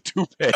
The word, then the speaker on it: toupee: I said tope, but it's a toupee.